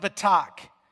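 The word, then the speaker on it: betak (בטח): The Hebrew word for trust is pronounced betak.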